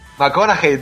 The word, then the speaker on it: McConaughey: McConaughey, tío. Yo me enteré en los Oscars porque yo decía McConaughey. – McConaughey. McConaughey. Es como hey. McConaughey. Te lo juro, te lo juro. Yo decía McConaughey toda la vida diciéndolo y no, lo decía mal, tío.